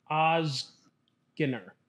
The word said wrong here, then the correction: Ozgener: Ozgener. – Ozgener.